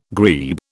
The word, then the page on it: grebe: British English